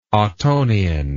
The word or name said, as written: ottonian